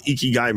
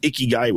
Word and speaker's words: ikigai: And the whole world likes to pronounce it wrong and call it ikigai, which hurts my face. It's ikigai, E as in good, ki as in life, gai as in way, good life way.